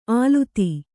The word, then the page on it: ಆಲುತಿ: āluti